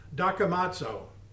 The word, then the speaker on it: dokimazo: That word is dokimazo, D-O-K-I-M-A-Z-O, pronounced dokimazo, dokimazo.